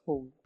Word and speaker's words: HODL: I think it's spelled HODL, but it's pronounced as hold, right? – Yes.